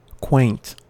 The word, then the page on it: quaint: American English